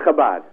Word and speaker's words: Chabad: Chabad.